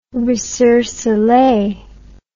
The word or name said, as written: recercelee